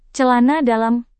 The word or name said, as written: celana dalam